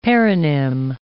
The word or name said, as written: paronym